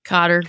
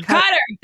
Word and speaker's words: Qatar: Cater? Qatar? Cotter. – Cotter. Thank you.